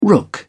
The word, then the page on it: rook: British English